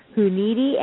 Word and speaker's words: Hunidi: I'm going to probably totally botch this, and I'm going to have her re-say it, but it's Maha Hunidi, and I know I probably just said it wrong because it's I'm not going to get the stress in the right place, so go ahead and say it again.